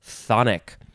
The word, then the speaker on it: chthonic: The first word in this episode is, it's spelled funny, but it's just pronounced thonic with a TH sound, thonic. Y is a word that has a TH sound at the beginning. At the beginning of this section in the C's, it is because it is spelled C-H-T-H-O-N-I-C, thonic.